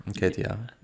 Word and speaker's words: Nketiah: I don't know if I'm pronouncing Eddie Nketiah. – Yeah, Nketiah.